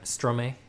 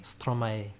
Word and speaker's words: Stromae: Like Stromae. Am I pronouncing his name right? – Stromae. – Was that close? – No. Not even close.